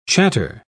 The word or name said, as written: chatter